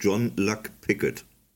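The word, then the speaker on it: Jean-Luc Picard: Die richtige Aussprache ist John Luck Pickett.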